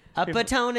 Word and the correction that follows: epitome: is that one? – Oh, yeah, epitome.